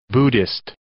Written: buddhist

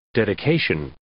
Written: dedication

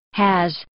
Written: has

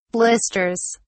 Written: blisters

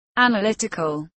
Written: analytical

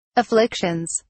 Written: afflictions

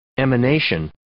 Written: emanation

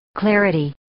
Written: clarity

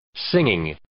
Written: singing

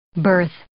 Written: birth

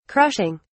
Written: crushing

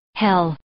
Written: hell

